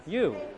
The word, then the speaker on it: ewe: You. That's how it's pronounced.